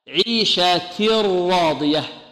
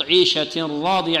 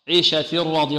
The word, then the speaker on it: عيشة راضية: راء خالصة، يعني ننتقل من تاء إلى راء مشددة. عيشة راضية عيشة راضية، أما نطق عيشة راضية عيشة راضية هذا الصوت من الأنف خطأ. عيشة راضية.